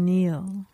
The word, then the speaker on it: kneel: K-N-E-E-L, Neil. – Raspberry, answer. – Silent right. letters. – Yes, silent letters. Right. Raspberry is the silent S. – P. – Well, P. Sorry. – Silent P, right? Answer. – W. – W and Neil. – Okay. – The K, which was silent until I spelled it earlier in the clue.